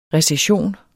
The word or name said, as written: recession